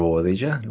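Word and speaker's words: Bobadilla: And this is Leonardo Bovadilla, rhymes with Casadilla.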